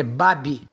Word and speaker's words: Baby: A pronúncia certa é Babi. Bravo Alfa Bravianque é Babi vem lá do sul, Paraná, que é da família do meu pai.